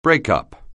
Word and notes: breakup: anglais

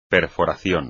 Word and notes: perforación: espagnol

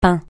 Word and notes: pain: francais

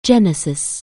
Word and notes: genesis: anglais

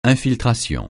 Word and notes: infiltration: francais

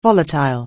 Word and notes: void: anglais

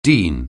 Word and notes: dean: anglais

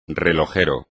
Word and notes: relojero: espagnol

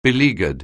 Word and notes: beleaguered: anglais

